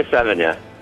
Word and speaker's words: Sevigny: Well, no, I have never dated a Savini. And is that how you say it, or 70? – Damned if I know. – Okay. Well, you've led a fantastic... – I mean... Chloe Sevigny.